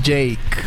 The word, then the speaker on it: JVKE: Con el joven Jake. J-V-K-E, pronunciado Jake.